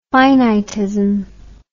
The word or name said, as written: finitist